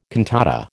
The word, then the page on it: cantata: American English